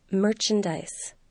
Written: merchandise

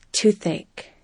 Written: toothache

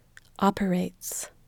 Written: operates

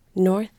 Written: north

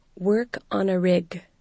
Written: work on a rig

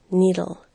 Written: needle

